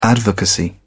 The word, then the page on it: advocacy: British English